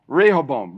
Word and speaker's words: Rehoboam: How do I say that? Rehoboam. Rehoboam. Thank you.